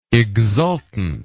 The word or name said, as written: exultantly